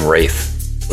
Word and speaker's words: Ralph: I think he pronounces his name Rafe.